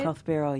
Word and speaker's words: Koth Biral: Tell me about Koth Biral. Is that how you say it? – Koth Biral, yeah.